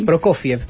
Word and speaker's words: Prokofiev: Dice Prokofiev, ¿se dice así? – Prokofiev, sí.